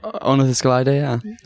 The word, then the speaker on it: Ornithoscelida: So we're joined and he is the author of the somewhat controversial Ornithoscelida. Is that how you say it? – Ornithoscelida, yeah.